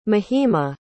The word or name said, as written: mahima